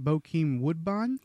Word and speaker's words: Bokeem Woodbine: Bokeem Woodbine.